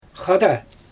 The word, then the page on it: xada: Xyzyl